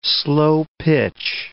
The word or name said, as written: slo-pitch